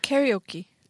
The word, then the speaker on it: karaoke: 우리는 가라오케라 그러는데 영어 발음이 좀 까다로워요. 가라오케가 가라오케 어떻게 발음해요? – 캐리오키. – 캐리오키. 캐리오키. 어, 캐리오키. 네, 요게 음. 가라오케예요. 노래방. – 음. – 캐리오키. – 캐리오키. – 캐리오키. 어, 발음 진짜 이상하다. 가라오케 그럼 못 알아듣겠죠? – 가라오케.